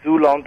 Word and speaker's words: Xulon: Zulon Press is X. U L O N Press, P R E S S, Zulon Press Self Publishers. – .com? – Yes. – Oh, good thing I asked how to spell that one. – Yeah, I would have put a Z in there myself.